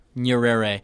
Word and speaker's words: Nyerere: Nyerere.